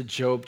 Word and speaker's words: Job: If you're unfamiliar with the Bible or maybe you're new to church, Job is kind of towards the middle of the Bible, right before Psalms. And it's kind of tricky because it's spelled just like the word job.